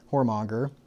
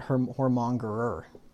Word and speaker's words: whoremonger: It says, but the fearful and unbelieving and the abominable and murderers and whoremongers, that's like this guy we're reading about here in Proverbs 5, whoremonger. Did I say it right? Mm-hmm. Whoremonger. Okay. Mm-hmm. Not whoremongerer. I've been saying that wrong forever.